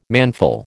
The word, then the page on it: manful: American English